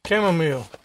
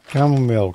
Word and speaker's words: chamomile: How do you pronounce that? – Chamomile. – Chamomile.